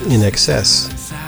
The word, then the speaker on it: INXS: It's pronounced in excess.